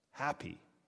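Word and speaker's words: Hapi: Happy, not H-A-P-P-Y, H-A-P-I is how it's spelled.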